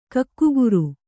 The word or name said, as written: ಕಕ್ಕುಗುರು